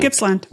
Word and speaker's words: Gippsland: Did I say that right? Probably yep. – Wrong. I got it? – No, man, Gippsland.